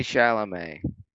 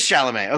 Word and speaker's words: Chalamet: How do you say his name? Yeah, Timothy Chalamet. – It is Chalamet.